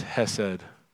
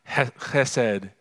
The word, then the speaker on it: hesed: The word there is hesed. If you heard Hunter Quinn pronounce it correctly, it's hesed. Hesed.